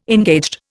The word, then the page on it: engaged: Indian